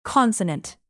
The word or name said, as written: consonant